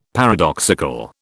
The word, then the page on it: paradoxical: British English